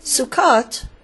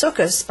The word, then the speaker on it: Sukkot: Sukkot, pronounced Sukkos by Ashkenazi Jews, is a biblical Jewish holiday celebrated on the 15th day of the month of Tishrei, between late September and late October.